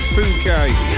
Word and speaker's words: Phuket: And let's not read it out the way it's spelt. We've not long started, have we? So, yeah, I think it's Phuket. We'll leave it at that.